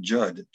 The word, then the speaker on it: Jud: I get a lot of mail to me, to Brian Judd, J-U-D, where I spell it J-U-D. And those who sell, so I will ask, is Brian Judd still there?